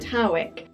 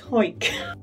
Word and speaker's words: Howick: Now, some people pronounce it Howick, but a quick Google has taught me that people from Hoik tend to call it Hoik.